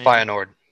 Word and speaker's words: Feyenoord: Yeah, so they just announced their first signing, Tyrell Malacia from, I can never pronounce this team's name, Feyenoord. – Feyenoord, for 15 mil.